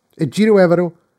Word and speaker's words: Ejiro Evero: Ejiro Evero